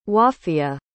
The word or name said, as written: wafiya